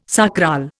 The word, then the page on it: sacral: Indian